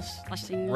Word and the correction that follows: Scherzinger: Scherzinger. It's not even close to Schlesinger.